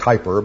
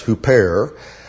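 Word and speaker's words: huper (ὑπέρ): Looks like hyper, but I'm told it's pronounced huper.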